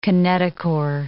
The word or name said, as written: kinetochore